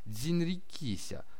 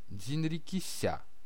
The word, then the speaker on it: дзинрикися: Само слово, лишь упрощенный для непривычного западного горла вариант, полностью произносится «дзинрикися» с ударением на последнем слоге «дзинрикися».